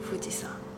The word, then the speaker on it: 富士山: 富吉桑，对，就是现在的富士山，读音是一样，既是不死山，也是富士山。